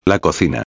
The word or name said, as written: la cocina